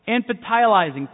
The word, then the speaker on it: infantilizing: So, my fear is that these technologies are infantizing, is that how you say it? Infantilizing, thank you very much.